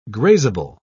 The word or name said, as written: grazeable